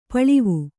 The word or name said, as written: ಪೇಲವ